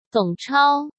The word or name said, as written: Dong Chao